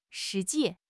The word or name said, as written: shi2 ji4